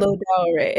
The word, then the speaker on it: Lodare: It'd be great to get into you know, how, you know, the man who came to, the man who sees tomorrow, and is it Lodare? Is that how I'm saying it right? – Lodare. It's in the Edo language, which is my family's like dialect, my family's language.